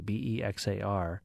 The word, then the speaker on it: Bexar: And also Bexar County, Bexar, is pronounced like the animal bear.